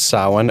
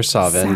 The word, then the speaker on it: Samhain: So the way that it's usually pronounced is Samhain or Samhain, depending upon which dialect you're speaking in.